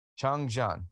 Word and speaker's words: Changjiang: Changjiang.